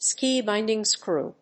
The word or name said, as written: ski binding screw